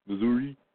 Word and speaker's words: Missouri: Missouri? – Uh-huh. Missouri, because it rhymes with misery.